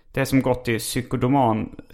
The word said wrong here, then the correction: psykodynamisk: psykodona, psykodynamisk tvärtom...